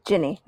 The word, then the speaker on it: Ginny: or it is Ginny, right? That's how you pronounce it? – Ginny, yeah. Yeah.